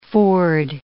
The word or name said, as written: ford